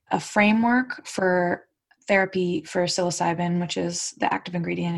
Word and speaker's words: psilocybin: one creates basically a cottage industry of new therapies out of, you corrected me during our pregame, psilocybin. Did I pronounce that correctly? – Psilocybin.